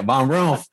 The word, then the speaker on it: VonRumpf: Yeah, you just kind of rolled it quick. VonRumpf.